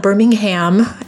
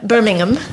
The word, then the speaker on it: Birmingham: It's not Birmingham, as we Americans are wont to say. It's Birmingham.